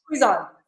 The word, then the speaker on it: Cuizon: Yes, Cuizon.